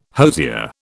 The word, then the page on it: hosier: British English